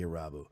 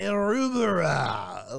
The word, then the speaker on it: Irabu: Hideki Irubara, Like, oh, he butchered it.